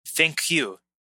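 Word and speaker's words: ThinQ: So we talked about it when it was announced, but now the reviews are out of the LG G7 THINCQ, which is pronounced thank you.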